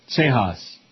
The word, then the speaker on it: Cejas: Cejas. That's that silent J in Espanol. Like Paco, every time we go into a restaurant, he orders apple juice. – The J is pronounced like an H.